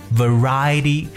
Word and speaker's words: variety: variety 这个单词在读的时候特别注意一下，这个 v 的发音是要咬住嘴唇的。 variety show，alright，variety show 综艺节目。